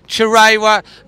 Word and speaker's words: Chirewa: And, you know, young Chiroa, is that how I pronounce it properly? Chirewa, who's made his debut.